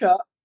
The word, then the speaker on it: Anchor: Also wir wollten eigentlich das Ganze nur über Anachor, Anachor, wie auch immer es auch ausgesprochen wird. Anchor. – Anchor.